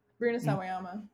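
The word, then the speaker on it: Rina Sawayama: Rena Sawayama.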